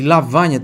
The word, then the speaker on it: lavagna: E lavagna tem o GN que tem som de NH.